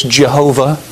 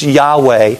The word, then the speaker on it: Yahweh: And an English pronunciation, you may have heard this, Jehovah. But the Hebrew pronunciation, thanks to the Masoretes for about 1,000 years after the coming of Christ, who put vowel points in there for us so we know how to pronounce these Hebrew words. The Hebrew pronunciation is Yahweh.